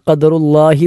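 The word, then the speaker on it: ഖദറുല്ലാഹ് (قدر الله): ഒന്ന്, ആദ്യത്തെ ഖദറുല്ലാഹ് എന്നിടത്തെ ഖാഫാണ്. ഖാഫ് എന്നത് അണ്ണാക്കിൽ നിന്നും പുറപ്പെടേണ്ട അക്ഷരമാണ്. ഖാ എന്നത് ഖദറുല്ലാഹ് എന്ന് പറഞ്ഞാൽ തെറ്റാകും. ഖാഫാണ് ഖദറുല്ലാഹ്.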